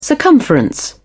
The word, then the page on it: circumference: British English